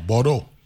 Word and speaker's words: Bordeaux: Bordeaux.